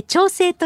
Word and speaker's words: 長生: 今週はご意見をいただいた方の中から抽選で毎日 5 人の方に、長生きと書いて調整と読む千葉県の調整地域 7 市町村から農産物と特産品の詰め合わせ、長生きボックスをプレゼントします。